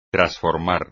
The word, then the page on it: trasformar: espagnol